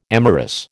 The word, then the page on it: amorous: American English